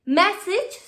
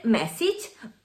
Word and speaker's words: message: No se dice message, se dice message.